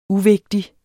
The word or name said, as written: uvigtig